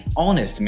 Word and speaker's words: honest: Já as palavras x-ray, SOS, e Honest Man começam com consoantes, mas na sua pronúncia tem o som de E e O, respectivamente.